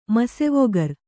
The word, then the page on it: ಮಸೆವೊಗರ್: kannada